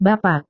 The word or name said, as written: bapak